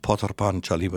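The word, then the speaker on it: Pather Panchali: Wird Pater Panchali geschrieben. Potar Panchali wird es ausgesprochen, das ist Bengali.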